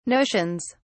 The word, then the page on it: notions: British English